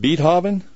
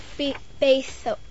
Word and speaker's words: Beethoven: And can you explain, this is a guy named Beethoven? Is that how you pronounce his name? – No, Beethoven. – See, I even got you wrong now. Beethoven, that's correct.